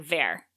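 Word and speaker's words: Vere: And so that's why I'm saying Vera, because perfect. – It's like a shortened verity.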